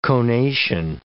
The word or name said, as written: conation